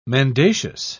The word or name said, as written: mendacious